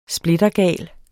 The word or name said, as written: splittergal